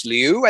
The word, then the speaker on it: Liu: It stars Havana Rose Liu, I think is how you pronounce it. She was really good. And nailed then Dale, it. What's that? – Nailed it. – Did I? Totally. Thank you.